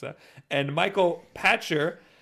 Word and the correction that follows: Pachter: analyst. What now? – Pachter. Michael Pachter.